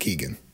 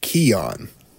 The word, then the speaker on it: Keoghan: It's not Keegan. – Oh, it's Keoghan? – Nope. – What is it? – Keon. – Keon? – Keon. – What? Okay. – Barry Keon.